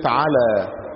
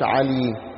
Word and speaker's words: على: وفي اللغه العربيه احيانا حرف اليه يتنطق الف، كلمه على عين لام يه، ليه بنقول على ما نقولش علي؟ لان اللي قبلنا نطقوها كده.